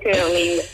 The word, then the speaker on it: curling: Diciamo che la pronuncia corretta sarebbe curling.